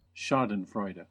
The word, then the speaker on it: Schadenfreude: And I did a little bit of research on this, and I found the correct pronunciation. It's this one. – Schadenfreude.